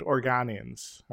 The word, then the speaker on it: Organians: They, the uh, not or, uh, Oregonians but Organians are. I'm mispronouncing it.